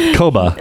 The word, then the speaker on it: Koba: You said it wrong so many times, I can't remember it. – Yeah. Kubo. – Yeah, whatever. – Eruption. – Koba. – There it is.